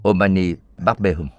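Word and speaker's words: Om mani padme hum: omani bát bê đây là phát âm theo Tây Tạng Tôi thỉnh giáo ngài Câu này có ý nghĩa gì?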